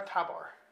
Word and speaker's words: Tabor: What was the pronunciation? – Har Tabor. – Tabor, oh, okay. – Tabor. Sorry. Yeah,